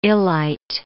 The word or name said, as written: illite